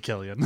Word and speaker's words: Cillian: Silly Killian. – Whatever. – You should spell his name with a K if you want someone to pronounce Killian it correctly.